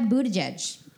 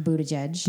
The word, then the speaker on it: Buttigieg: Next, we have Buttigieg. That is the correct pronunciation of Buttigieg. – Buttigieg.